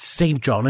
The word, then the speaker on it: St. John: No, it's not Street John. It's St. John.